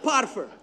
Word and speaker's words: Potiphar: You gonna say Potiphar like a white boy, huh? Potiphar. I'm okay saying like a white boy? Potiphar. – You pass. You're good.